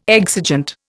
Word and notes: exigent: Indian

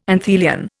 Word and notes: anthelion: Indian

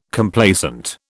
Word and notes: Complacent: British English

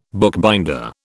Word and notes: bookbinder: British English

